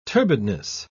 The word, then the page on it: turbidness: British English